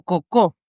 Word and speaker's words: Coco: porque de esa soledad nacerá Coco, o Cocó, en realidad, como sería bien pronunciado.